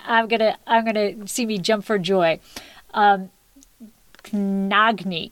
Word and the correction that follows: Khndoghni: Not even close. Khndoghni? – Oh, Khndoghni. Yes. Yeah, so the transliteration doesn't make much sense. That's not you. That's sort of all those syllables next to one another. You know? It's in English, so it's not you.